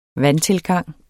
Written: vandtilgang

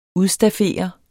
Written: udstaffere

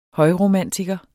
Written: højromantiker

